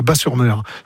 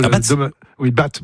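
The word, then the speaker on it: Batz-sur-Mer: Et surtout, la crêperie s'appelle le Dervin à Bas-sur-Mer, qui font les meilleures crêpes de Bat. Bon, on prononce Bas, le T ne se prononce pas.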